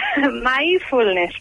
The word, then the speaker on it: mindfulness: Mindfulness.